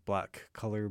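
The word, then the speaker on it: Blac Kolor: and that is Black Color, B-L-A-C, and Color is spelled K-O-L-O-R.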